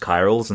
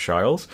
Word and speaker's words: chirals: Oh, by the way, for pronunciation, it's just chirals instead of shiles.